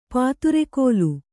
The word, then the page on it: ಪದರುಗಲ್ಲು: padaru gallu